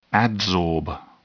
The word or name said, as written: adsorb